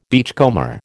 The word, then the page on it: beachcomber: American English